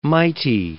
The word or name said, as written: mighty